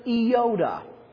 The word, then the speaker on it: iota: It's an iota. Iota. The only thing is, it doesn't have a dot at the top, but it looks like an I, and it's pronounced like the small I in pit.